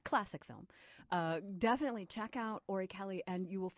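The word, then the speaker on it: Orry-Kelly: And that is spelled just like it sounds, Ori Kelly, who was another great designer, also known for really influencing fashion and using kind of high fashion within the context of films.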